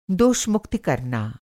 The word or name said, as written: दोषमुक्ति